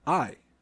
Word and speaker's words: aye: Number three, we have I, or I. – I like it. – Which is spelled A-Y-E. – A-Y-E. – A-Y-E.